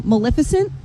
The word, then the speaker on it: Maleficent: Green is Maleficent.